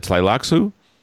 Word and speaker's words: Tleilaxu: The ha- expansion is uh, Ixians and the Tleilaxu.